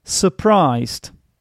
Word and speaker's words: surprised: Surprised has the schwa sound in the first syllable. Surprised. Now, you have to remember something about the schwa sound, ladies and gentlemen. It is never ever stressed in the English language. Especially when it's at the beginning of a word, this basically means that the following syllable is stressed instead. So you don't say surprised or something like that. You should say surprised.